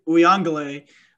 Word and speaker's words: Uiagalelei: DJ Uiagalelei, nailed it again on the pronunciation, is going to be just as good as he was last week.